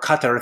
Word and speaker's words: Qatar: So for example, in Qatar, or Qatar, if you want to pronounce it that way, they have this new solar project that came out at 1.57 cents a kWh.